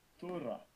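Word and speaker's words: Thura (θύρα): Thura.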